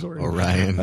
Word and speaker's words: Orion: and I didn't even pronounce it Orion. It was Orion.